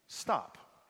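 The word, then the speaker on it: Stop (стоп): But now, once what was once hidden is now revealed, and now as you're driving, you're probably going to stop. Now, if you sound this out in Cyrillic, you know what it says? Stop. That's how you pronounce it. Yeah, S-T-O-P. Yeah, it's stop.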